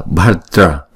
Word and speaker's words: bhadra: Thì chúng ta đọc nhanh á là bhadra bhadra chứ không phải là bạc mà là bha bhadra bhadra.